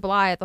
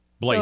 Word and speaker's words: Blathe: So it's Blathe, which I said Blythe the whole time. He says, say Blay, then make the th sound. – Blathe.